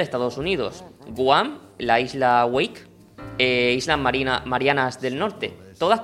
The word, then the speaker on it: Wake: Antes de entrar en el debate Biden-Trump, me gustaría agradecerte que hayas pronunciado Wake, porque yo iba a decir Wake. – Ya, no sabía decir Wake, Waka o Wake, pero... – No, pero Wake está muy bien pronunciado.